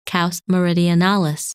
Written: Kaus Merianalis

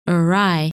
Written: Er Rai

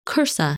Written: Cursa

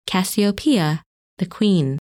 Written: Cassiopeia

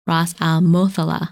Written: Rasalmothallah

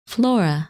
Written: Flora

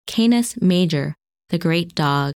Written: Canis Major

